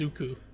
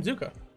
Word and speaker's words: Zuko: So I would love to talk to you about Prince Zuko. – Okay. Zonka. – Zuko. – Prince Zonka. Zuko. Danny Zuko. The guy from Greece. – That's legitimately, that's how you say his name, Zuko.